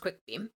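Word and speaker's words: cwicbeam: And then the Old English name for the Rowan tree is Quickbeam, C-W-I-C. – Oh, interesting. – But it's pronounced Quickbeam.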